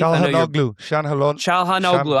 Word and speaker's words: Çalhanoğlu: Hakan, I'm going to butcher his last name, Çalhanoğlu. Is that how you pronounce it? – Çalhanoğlu. – I got it really close.